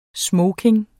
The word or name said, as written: smoking